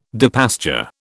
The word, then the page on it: depasture: British English